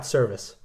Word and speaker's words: Servais: It's Scott Servais. – Well, it's spelled S-E-R-V-A-I-S. – Yeah, he's a major league catcher. – Okay. – For the longest time. Servais. – Servais is what I said.